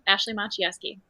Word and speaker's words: Ashleigh Maciejewski: Ashleigh Maciejewski.